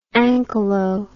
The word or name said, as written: ankylo